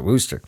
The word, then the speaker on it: Worcester: Can I say Worcestershire? And he goes, yeah, Worcestershire is okay. That kind of works. That's kind of a layman term. He's like, but you actually pronounce it Worcester.